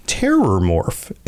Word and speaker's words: Terrormorph: So, yeah, so maybe you're mishearing it while you play through the game, because it seems like a lot of people do, but it's actually Terrormorph.